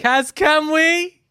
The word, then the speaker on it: Kaz Kamwi: Wait, no, you need to say it properly. – Kaz Kamwi.